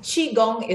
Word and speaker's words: Qigong: It's actually Qi. Qi. It's Q-I, but okay. it's often mispronounced, but that's the pinyin version. So it's not important. C-H-I, I think in English, is the closest sound to Qigong.